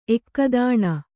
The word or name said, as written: ಏಕತಾನ